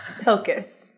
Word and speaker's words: Pilkes: It's spelled P-I-L-K-E-S, which looks like Pikes or Pilks. It doesn't look like what it sounds like.